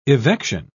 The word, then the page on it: evection: British English